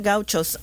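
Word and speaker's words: gaucho: I've read about, what are they, gauchos? Am I saying that correctly? – Yeah, the gauchos.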